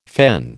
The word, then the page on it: fen: American English